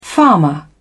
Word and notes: farmer: British English

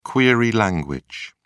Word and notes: query language: British English

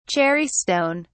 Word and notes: cherry stone: British English

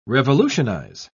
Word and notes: revolutionise: British English

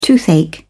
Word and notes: toothache: British English

